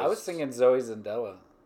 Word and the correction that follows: Saldana: Saldana, that's how you say it, yeah.